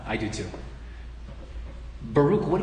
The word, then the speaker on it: Baruch: It's not Barack, it's Baruch. That's all right though, we mispronounce things.